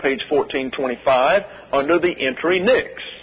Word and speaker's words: Pnyx: Therefore, you have the English translation P-N-Y-X, pronounced Nyx.